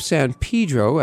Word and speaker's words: San Pedro: The city of San Pedro, as it's pronounced locally, is on LA's southern edge, along what is known as San Pedro Bay.